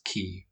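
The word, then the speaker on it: Key (Kingdom Hearts χ): The game includes a remastered version of Dream Drop Distance, as well as cinematic retelling of the events of Kingdom Hearts Key, or X, as it's spelled.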